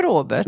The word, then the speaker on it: Aubert: Jag tror vi bestämde här. oss för Aubert. – Vi bestämde oss för Aubert och håller vi fast vid det.